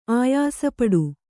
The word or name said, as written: ಆಯಾಸಪಡು